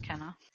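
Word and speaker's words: quena: It's a Kenna. It's pronounced Kenna.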